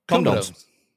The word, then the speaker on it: comedones: Comedones.